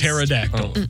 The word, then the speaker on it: pterodactyl: T-E-O-T-A? T-E-T-A? T-Y? – There's a silent P on the front of that. – Oh, my God. Is there really? – Yeah. – P-T-E-R-O-D-A-C-T-Y-L. – That was just... – Pterodactyl.